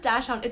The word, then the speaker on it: dachshund: Okay, so earlier you called it a Dutch hound. – I know. I didn't know how to pronounce it. How do you pronounce it? – Andy says Dash Hound. It's a dachshund.